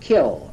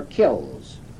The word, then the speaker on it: kilns: And that term, although it's spelled K-I-L-N-S, was pronounced kill or kills.